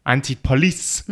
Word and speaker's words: Antipolis: Antipolis.